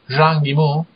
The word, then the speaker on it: Zhang Yimou: Passiamo al successivo, quindi Sangue Facile di Zhang Yimou, come si pronuncia? – Sì, va bene.